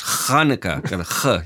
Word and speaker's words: Hanukkah: Yeah, and then let's also get the pronunciation right. It's Hanukkah. You got to get the Hanukkah.